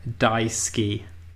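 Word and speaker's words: daisugi: My topic this week, for which I would like your first thoughts, is something called Daisuke. And that is the correct pronunciation, but I'm also going to spell it, not phonetically, but, you know, kind of spell it for you.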